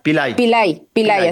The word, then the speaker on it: Pillay: Se llama Nabi... Pali, Pillay, así se pronuncia.